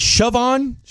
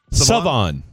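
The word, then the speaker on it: Shavon Revel: Is it Siobhan? – Siobhan Revel? – Savon. Like Savant, but Savon? – Okay.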